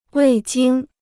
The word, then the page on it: 味精: wèi jīng